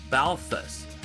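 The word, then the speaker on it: BALTHVS: Balthus rhymes with all fuss. The T-H makes a soft sound, and the V in the stylized name is simply pronounced as a U.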